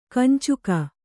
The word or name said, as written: ಕಂಚುಕ